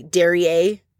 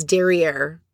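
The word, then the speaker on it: derrière: And then right out the gate, Ramona mentions the derriere. And finally, they all look at her. Ramona, it's derriere.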